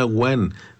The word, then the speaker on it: one: Y nos dice: aquí suena Juan, pero es One. Nos escribe O-N-E, como se escribe en inglés, se pronuncia Juan en inglés, perfecto.